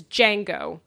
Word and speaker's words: Django: Django, it's Django. It had the D is silent. – The D is silent, yeah.